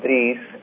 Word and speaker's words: Dries: Actually, in Arabic, it's pronounced Idris, and in Morocco, we have a version of that name, and so it is Dries.